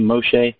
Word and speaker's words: Moshe: I use the name Moshe because that's how it's pronounced in Hebrew.